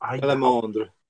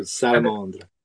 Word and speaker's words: Salamandre: And then I'm just gonna ask again, before we started the interview, your book, I'm trying to find the correct pronunciation, but I'm gonna do the American version. I know I call it Salamandre. Again, may I ask, can you pronounce the French version again? The French, Salamandre. – Okay. – So Salamandre.